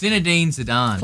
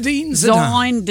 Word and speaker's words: Zinedine Zidane: It's Zinedine Zidane. – Close enough. – Zinedine Zidane.